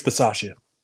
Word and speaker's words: Bisaccia: Is that how you say it? – Rich Bisaccia. – Okay, gotcha.